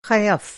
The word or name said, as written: ख़यफ़